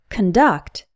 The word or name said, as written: conduct